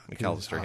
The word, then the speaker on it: McCallister: McCallister? – Yeah, McCallister, yeah.